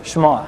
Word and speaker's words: Shema: Shema, as in listen, ends with an ayin, and you pronounce it Shema.